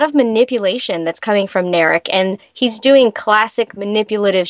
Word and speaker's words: Narek: And I think that this thing that's going on, would you say his name is Narek? – Narek is the Romulan.